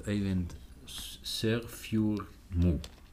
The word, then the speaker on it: Øyvind Sørfjordmo: We have Øyvind Sørfjordmo. – Yes. – Did I say it right? – Yeah.